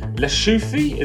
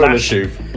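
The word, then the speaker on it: La Chouffe: I've heard Le Chouff before, but I think Le Chouffe might be the one. – Yeah, let's do Le Chouffe.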